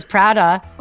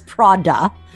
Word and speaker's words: Prada: You know, The Devil Wears Prada. Oh my God, I just pronounced that totally wrong. The Devil Wears Prada. Mm-hmm. I know it's not an ah, it's an aw.